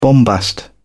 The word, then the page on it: bombast: British English